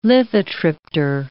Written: lithotripter

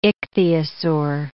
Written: ichthyosaur

